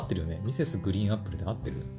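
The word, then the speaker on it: Mrs. GREEN APPLE: ミセスグリーンアップルだ。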